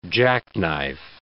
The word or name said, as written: jackknife